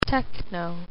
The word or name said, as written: techno